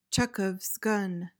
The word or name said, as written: Chekhov's gun